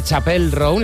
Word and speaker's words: Chappell Roan: Ella misma nos explica cómo se pronuncia. – Chappell. Roan. Chappell. – Okay. Ah, pues lo digo mal yo.